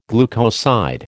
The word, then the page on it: glucoside: American English